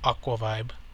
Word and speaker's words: Aquavibe: and his name is D1 of Aquavive. And he's friends with a lot of gaming YouTubers, and like what he'll do is Aquavibe, I'm sorry I said that wrong, it's D1 of Aquavibe.